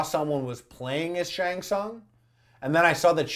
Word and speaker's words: Tsung: First of all, I don't even want to tell you how I pronounced his name when I was a kid. You know that T got pronounced hard. – Tsung, yeah.